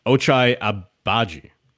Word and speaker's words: Ochai Agbaji: And maybe we'll get to that in a second, but they also acquired Ochai Agbaji.